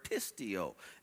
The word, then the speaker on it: pisteuo: This word believe here is the word Greek word pisteuo,